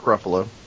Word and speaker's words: Ruffalo: it's Mark Ruffalo. Okay, all right, very yeah, good. – I just like, like a buffalo.